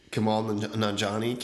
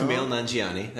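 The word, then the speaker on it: Kumail Nanjiani: Kamal Nanjiani came out. – Kamal Nanjiani.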